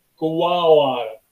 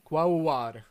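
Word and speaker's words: Quaoar: A čte se to UA je dvojité V, takže to čte nějak jako Kuaor. – Kuaor, ok. – Kuaor. Něco jako kávovar v podstatě, akorát...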